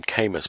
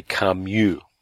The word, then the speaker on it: Camus: He's talking about a philosopher, and he calls him Camus, but it's actually Camus. So he mispronounced the name of a philosopher while giving a lecture at a university.